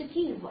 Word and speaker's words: give: 啊，注意这个词，大家注意在这个啊元音的发音当中呢，是发的这个 d 这种用法，而不是大家想象的 if，也不是 give 啊，是 give 啊，很短促的音。